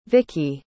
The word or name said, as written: vicki